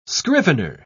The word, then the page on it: scrivener: British English